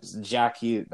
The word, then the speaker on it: Jack Hughes: I did it. I said it right. – Yeah. – Jack Hughes.